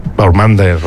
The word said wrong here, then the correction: Baumgartner: o como se pronuncia. – Baumgartner, sí.